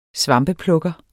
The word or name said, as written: svampeplukker